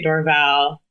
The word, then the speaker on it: Dorval: My whole family says it differently. I say Dorval. It's an airport, so I feel like you can feel how they say it. The airport employees, and that's probably how it's done.